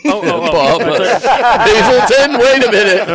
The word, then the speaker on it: Hazleton: It's spelled Hazleton, but it's pronounced Hazleton.